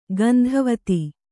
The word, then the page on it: ಗಂಧವತಿ: gandhavati